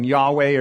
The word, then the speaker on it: Yahweh: It's pronounced often Yahweh or Jehovah.